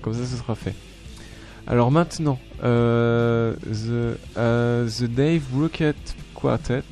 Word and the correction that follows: Dave Brubeck: Est-ce que je l'ai bien prononcé ? Dave Brubeck. Ouais, Dave Brubeck Quartet.